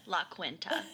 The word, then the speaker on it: La Quinta: It's pronounced La Quinta.